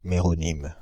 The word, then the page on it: méronyme: /me.ʁɔ.nim/